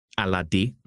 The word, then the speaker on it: Alade: My name is Charles Alade. Alade is a Yoruba name. Okay. I think that's all. But Charles Alade is how the name is pronounced. – Alade.